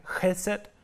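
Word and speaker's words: chesed (חסד): Det er et ord, som udtales noget i retning af chesed, øh, staves øh, enten C-H-E-S-E-D eller H-E-S-E-D.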